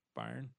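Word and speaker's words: Byrne: And athletic director Greg Bryan, is it Byron? Byron, yeah, has initiated the termination process for Bonahan.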